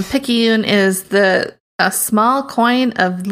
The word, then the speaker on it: Picayune: Picayune. – Picayune.